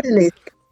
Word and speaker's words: Odalisque: Is that how you pronounce that, Odalisque, the middle word? – Odalisque. I do, yes.